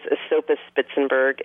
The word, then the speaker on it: Esopus Spitzenberg: The Esopus Spitzenberg, which was Thomas Jefferson's favorite apple, is a great name, and nobody ever knows how to pronounce it, but it is Esopus Spitzenberg.